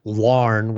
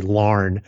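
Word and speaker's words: Laugharne: In 1976, I went to Wales, and in order to get to the little village where he lived, which is Larne, which is one syllable, but it's spelled like it's 20 syllables. it's L-A-U-G-H-A-R-N-E, Larne, a tiny village.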